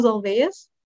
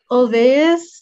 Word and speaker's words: Olweus: I know one of them was Olvais. – Tell me more. I'm not familiar. – Olvais is, how do I describe it? – Is it like a curriculum resource?